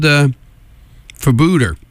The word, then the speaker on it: Febueder: And it's spelled F-E-B-U-E-D-E-R. And according to their Facebook page, it's pronounced like Bermuda, but with an F.